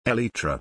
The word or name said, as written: Elytra